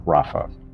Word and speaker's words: Rapha: The word heal in Hebrew literally means Rapha, or it is pronounced Rapha.